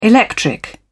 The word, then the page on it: electric: British English